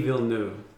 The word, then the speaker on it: Villeneuve: It's Denis Villeneuve. – I'm not even And I'm not that. French, so that probably sounded stupid and terrible. But that's the gist of how to say his name.